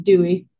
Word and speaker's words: Duwe: Almost. Damn near. It's Duwe.